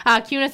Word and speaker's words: Kunis: So that is Kunz. Kunis. I always want to say it how it's spelled. Kunis RV of Wisconsin Rapids, obviously.